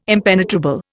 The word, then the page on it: impenetrable: Indian